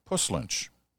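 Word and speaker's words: Puslinch: Anyway, Richard Sooner, who's written many times before, wants me to know, you know what, it's actually not Puss Lynch. The proper local pronunciation, says Richard, as is in puss, as in puss in boots, as opposed to the stuff that oozes from a wound.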